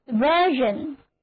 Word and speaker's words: version: 那么今天我们就从版本说起，版本就是 version， V-E-R-S-I-O-N， version，字母组合 E-R 发作 S 发作 Z，单词就读作 version，版本、译本，还可以指代说法。